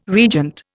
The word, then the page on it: regent: Indian